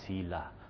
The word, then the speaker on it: Selah: I actually went to the online dictionary and pressed the pronunciation one where it actually says it for you, and it sounded like Selah.